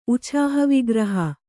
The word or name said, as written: ಉಛಾಹವಿಗ್ರಹ